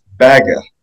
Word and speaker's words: Bagge: Well, Bach, who are renamed because his Swedish name is pronounced Bagge. It's B-A-G-G-E.